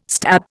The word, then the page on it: stab: Indian